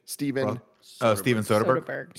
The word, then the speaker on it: Soderbergh: Steven Soderbergh.